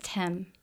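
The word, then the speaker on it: Thames: I have the pronunciation of the river, but it's Tem. – River Tem. – Tem, like T-E-M.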